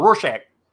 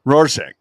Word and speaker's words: Rorschach: The Rosark, yeah, or was it Rosark? Rosarch. I can't even say it. I used to know how to say it. It's a weird name. – Oh, Rorschach. – Rorschach. There you go.